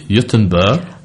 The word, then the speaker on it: Göteborg: Gothenburg, or I think it's pronounced Göteborg. In Swedish, that has another wonderful experience for you if you're looking for a Christmas retreat.